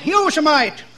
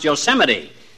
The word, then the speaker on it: Yosemite: Had a job on a ranch out there for a while up near that state park, uh, Yosemite. no, not Yosemite, old timer. That's pronounced Yosemite.